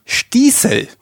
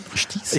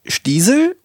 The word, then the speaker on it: Stießel: Es ist Stießel. Stießel. Ich dachte immer, es ist Stiesel oder Stissel. Also entweder lang oder kurz, aber nein, es ist das Zwischending, es ist das lange I, aber ein scharfes S.